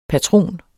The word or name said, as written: patron